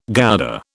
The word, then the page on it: Gouda: British English